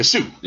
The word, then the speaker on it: Sioux: like the Sioux, I can't even say it right. The Sioux. Yeah. There we go. But it's not spelled. – No, it's a S-I-O-U-X.